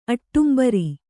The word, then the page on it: ಆಡಂಬರ: āḍambara